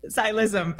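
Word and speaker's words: Ksi Lisims: the I'm going to say it wrong, Xylicillum. Xylism. Thank you.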